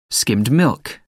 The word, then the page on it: skimmed milk: American English